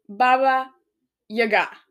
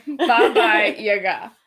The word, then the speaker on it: Baba Yaga: So, it's supposedly Baba Yaga. – Okay. – Baba Yaga.